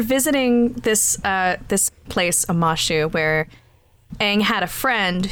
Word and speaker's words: Omashu: Omashu.